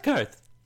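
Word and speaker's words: Qarth: And it's pronounced Karth.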